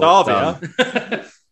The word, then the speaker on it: Savea: Is it Savea? – It is Ardi Savea. Well, Savea. That's how the Kiwis say it.